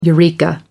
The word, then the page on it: eureka: American English